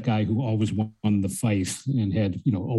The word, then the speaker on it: feis: He was a perennial winner of, I believe it's called FICE competitions, FEIS, in Derry, which is traditional Irish song and dance competitions.